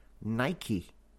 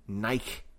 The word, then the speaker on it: Nike: Much of the world pronounce this word Nike. Nike. However, in Liverpool, we never say Nike. It is a real difference between how Liverpool pronounces this word in particular and the rest of the English-speaking world pronounce this word. So we say Nike. Nike. With a hard K sound.